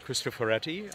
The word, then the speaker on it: Cristoforetti: Ms. Cristoforetti, I think. – Yes. – Did I pronounce it correctly? – Yep, Samantha, yeah.